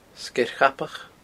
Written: Sgeir Chabach

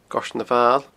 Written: Goirtean Amhail

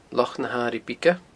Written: Loch na h-Airighe Bige

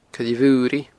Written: Coille Mhuiridh

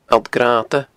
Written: alltgrannda

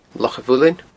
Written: Loch a' Mhuilinn

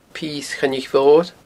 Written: Pios Choinnich Mhoir

